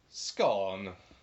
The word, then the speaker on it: scone: What's the fastest cake in the world? Scone. – Thank you. So that, according to a Scotsman, is how you pronounce scone.